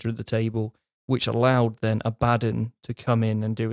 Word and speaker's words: Abaddon: And it is the way it's spelled, it should be Abaddon. – Abaddon.